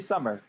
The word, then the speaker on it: Sommer: It's actually Sommer, but Sommer. That happens all the time.